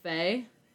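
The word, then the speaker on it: Fei: And I believe it's pronounced Faye.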